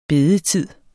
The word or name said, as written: bedetid